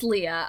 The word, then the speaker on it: Leah: Now, when you say your name, is it more on the Leah side, or should it be more on the Leia side? – No, it's Leah.